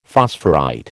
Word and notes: phosphorite: American English

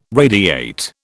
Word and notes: radiate: British English

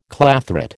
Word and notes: clathrate: American English

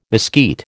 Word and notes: mesquite: American English

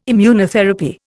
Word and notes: Immunotherapy: Indian

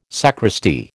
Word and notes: sacristy: American English

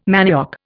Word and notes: manioc: Indian

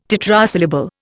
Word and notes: tetrasyllable: Indian

